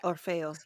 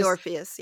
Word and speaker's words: Orfeo: Is that how it's pronounced? – Yeah, Orfeo. Yeah. – Because I was just gonna say Orpheus. – Yeah, you can say Orpheus.